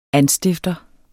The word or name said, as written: anstifter